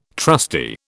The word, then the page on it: trusty: British English